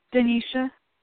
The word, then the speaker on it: Danisha: Denisha. – How do you spell it? – D-A-N-I-S-H-A.